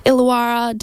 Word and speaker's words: Illawarra: They're from Illawarra. Did I say it right? Yeah, I'm going to give myself the tick. – Yeah, good job. – Illawarra, down in New South Wales, a duo.